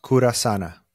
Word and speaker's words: Curasana: Yeah, the name is pronounced Curasana. Okay. So it's Curasane. And the name, it's the combination of two Spanish words that mean to heal, kind of in different ways.